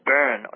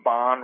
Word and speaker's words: Bön: Before Buddhism before Buddhism was introduced, they believed in a religion, a cult, a shamanistic cult called Bön. Some had pronounced Bon religion or Bone religion.